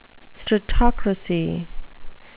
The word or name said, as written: stratocracy